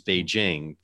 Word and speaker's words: Beijing: One of the things that first of all drives me crazy is when people pronounce Chinese names wrong, like the capital of China is not Beijing, it's Beijing. The J is pronounced like a J, or the leader of China is not Z, it's she, like S-H-E.